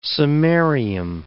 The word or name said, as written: samarium